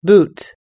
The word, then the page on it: boot: American English